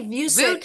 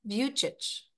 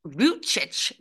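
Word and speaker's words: Vučić: You can say Vucic Vucic. – because you're American, but if you're Croatian, you say Vucic. – Vucic.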